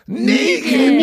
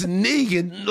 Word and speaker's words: Knigin: K-N-I-G-I-N. – G-I-N, yes. – It's Negan. – Negan. – Me. No, you for some reason you said it right now, but you didn't. – Okay. – Like, it's Negan, like from Walking Dead?